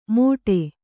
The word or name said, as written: ಮೋಡೆ